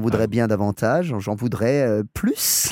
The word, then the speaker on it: plus: But the crucial thing, if you're using plus, is that you pronounce that S. – Oh, you've got to. Oh, yes. – Because if you don't pronounce the S, it means something different.